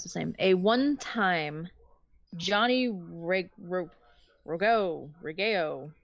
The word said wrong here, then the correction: Rougeau: Tag Team Champion. – Rougeau. – We're going to go with that. Okay, or that. – Did you say Rougeau? – Yeah, spell it out for us.